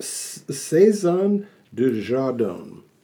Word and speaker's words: Saison de Jardin: It's pronounced Saison de Jardin. That's as much French as I know.